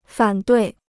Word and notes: 反对: fǎn duì

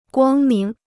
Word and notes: 光明: guāng míng

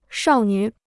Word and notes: 少女: shào nǚ

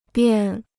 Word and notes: 便: biàn

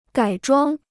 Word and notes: 改装: gǎi zhuāng

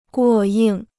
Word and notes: 过硬: guò yìng

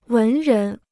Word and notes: 文人: wén rén

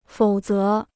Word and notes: 否则: fǒu zé

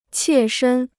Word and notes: 切身: qiè shēn

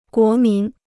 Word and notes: 国民: guó mín

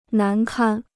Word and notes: 难堪: nán kān

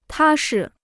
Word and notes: 踏实: tā shi

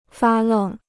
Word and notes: 发愣: fā lèng

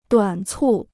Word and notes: 短促: duǎn cù

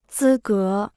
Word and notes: 资格: zī gé

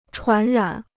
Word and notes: 传染: chuán rǎn

